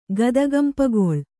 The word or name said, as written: ಗದಗಂಪಗೊಳ್